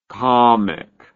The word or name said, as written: camik